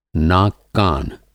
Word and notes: नाक-कान: Hindi

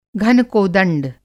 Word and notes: घनकोदंड: Hindi

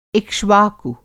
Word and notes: इक्ष्वाकु: Hindi

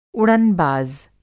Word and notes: उड़न-बाज़: Hindi

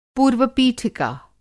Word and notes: पूर्व-पीठिका: Hindi

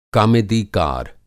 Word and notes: कामेदीकार: Kamedikar

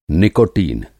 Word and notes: निकोटीन: نیکوٹین

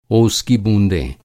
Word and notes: बूँद: بوند

